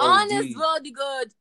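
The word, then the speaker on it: Anos Voldigoad: Honest Roddy God. You have to say it like that.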